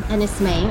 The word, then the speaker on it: Enys Men: Yeah, it's Maine rather than men. It's spelled men, pronounced Maine, because it's Cornish word for stone.